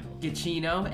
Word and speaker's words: Giacchino: Giacchino, and I finally learned how to say his last name. Giacchino, Michael Giacchino.